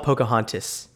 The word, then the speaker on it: Pocahontas: We start in Pocosun, Virginia. Am I saying that right? – Never heard of it. It's called Pocahontas.